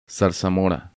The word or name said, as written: Zarzamora